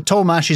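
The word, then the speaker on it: Tollemache: Well, yeah, well, Tollmash is not spelled Tollmash. I'm definitely not pronouncing it as how it's spelled.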